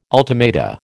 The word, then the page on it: ultimata: American English